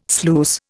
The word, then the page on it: sluice: Indian